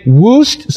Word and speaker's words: Wuest: You might pronounce his name Woost. Some might know it in that way. It is spelled W-U-E-S-T, and I would suspect that some of you know of his work.